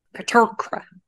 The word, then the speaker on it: Petrarca: Aired January 30th, 2002, written by Rita Mimoun and directed by David Petrarca. I never say his name right. Petrarca. – Petrarca.